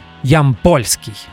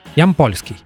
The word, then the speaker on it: Ямпольский: Очень просто, маленькие буквы, без пробелов, Art Ямпольский.